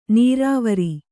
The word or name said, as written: ನೀರುವರಿ